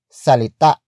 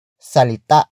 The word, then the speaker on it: salita: It comes from the root word salita. Salita. Salita, which is spelled as S-A-L-I-T-A.